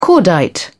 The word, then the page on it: cordite: British English